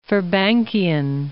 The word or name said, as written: Firbankian